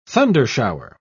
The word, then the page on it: thundershower: British English